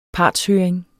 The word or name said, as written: partshøring